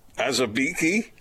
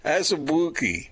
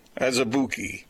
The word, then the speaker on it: Azubuike: Azubuike? – Nope. – How do you say it? How do you say it? Azubuike. – Don't hurt yourself. – Azubuike? – Closer. – Azubuike.